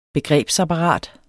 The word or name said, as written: begrebsapparat